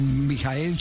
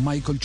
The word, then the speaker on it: Michael: Así es, como Mijael Schumacher, no Michael Schumacher, sino Mijael Schumacher.